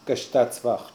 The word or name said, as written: Kshtatsvacht